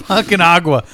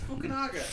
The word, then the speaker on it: Fukunaga: Your Japanese syllables. Read what actually the name is. – It's Fukunaga. – It's Fukunaga.